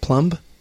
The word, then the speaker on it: Plumb: It's pronounced Plumb. – Star 88. – Plumb the line.